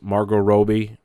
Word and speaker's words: Margot Robbie: Also in a minor role is Margot Robbie, which I recently found out is how you actually say her name, playing Sharon Tate, the woman famously murdered by the Manson family.